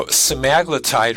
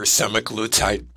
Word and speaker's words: semaglutide: So semaglutide or semaglutide, both are acceptable on how to pronounce the name of the drug.